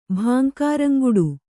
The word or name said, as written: ಭಾಂಕಾರಂಗುಡು